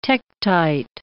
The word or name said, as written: tektite